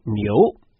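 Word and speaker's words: niu (牛): As the Chinese word for ox is pronounced niu, which sounds similar to new in English, but has the meaning of being bullish, this pun is interpreted as an auspicious start to the year.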